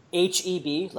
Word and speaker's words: HEB: HEB like Heb like the letters? I mean, it's not pronounced Heb, but it's like HEB. The letters HEB.